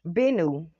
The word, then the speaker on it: Bennu: They compared this asteroid Bueno to being like that. Bennu. His name is Bennu. I always say his name wrong.